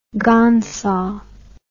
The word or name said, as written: gangsa